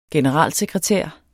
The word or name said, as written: general-